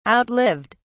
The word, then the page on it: outlived: British English